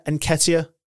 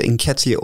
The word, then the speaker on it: Nketiah: Nketiah? – It's either Nketiah or Nketcher. I think it may be Nketcher.